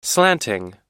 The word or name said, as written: slanting